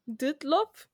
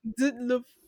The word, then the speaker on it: Dyatlov: Dutlov? – Dyatlov.